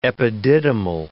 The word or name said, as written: epididymal